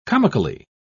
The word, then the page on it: comically: British English